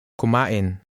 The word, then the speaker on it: kumain: Here's how to say, eat. Listen and repeat. – Kumain. Kumain. In. Ka-in. Kumain. Kumain.